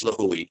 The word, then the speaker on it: Lihue: Lihue? Is that how you say it? – The main town, you're correct, from Kauai is Lihue.